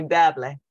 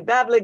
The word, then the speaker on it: Beverly: she never pronounced my name the way I understood it to be. She called me Beverly. And she would say, Beverly, go pick that and pick that.